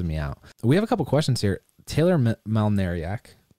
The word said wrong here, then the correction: Malnarek: Malnarek, sorry. I always mispronounce that.